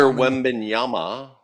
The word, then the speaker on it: Wembanyama: Wabanyama. – Victor Wembanyama.